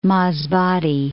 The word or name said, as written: Masbate